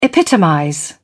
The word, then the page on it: epitomize: British English